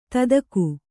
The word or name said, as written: ದಾಧಿಕ